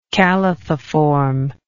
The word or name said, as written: calathiform